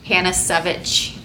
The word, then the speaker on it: Panasiewicz: It's a lot of letters, and it's not pronounced how it's spelt, so. – How do you pronounce it? – Panasiewicz.